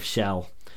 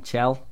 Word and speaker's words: Chell: You know you're pronouncing that wrong. – Chell? – That's it. – I'm sorry. – It's all right. It's but CH.